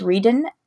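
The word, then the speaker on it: Wreden: So the Beginner's Guide, which was released in 2015 by Davey, I think it's pronounced Reden, everything by, yes.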